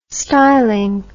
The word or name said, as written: stylin